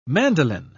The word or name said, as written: mandolin